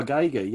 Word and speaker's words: Giger: Giger, right? yeah, yeah, yeah. So H.R. Giger, yeah.